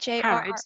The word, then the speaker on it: Harrods: Okay, well, have you heard of Harrods? Am I saying that right? – Harrods, yeah, it's a big department store in London.